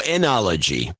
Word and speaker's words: enology: ITM, John and Adam, how could I pass up an opportunity to add a PhD in media deconstruction to my BS in onology? Is that how you pronounce it? Onology? What is onology? – I have no idea what onology is. – There's got to be something good. – Oh, enology.